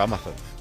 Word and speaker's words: Amazon: Oye, enhorabuena, Poli, has dicho Amazon. – ¿Lo he dicho bien? – Sí, es que normalmente decía...